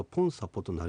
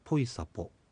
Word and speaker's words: ポイサポ: 例えばなんですけれども、年下の方のお姉さん、このお姉さんのことはポンという小さい言葉と、サポお姉さんという言葉で、そのまま発音するとポンサポとなりそうなんですが、続けて発音するとですね、この場合もポイサポと変化します。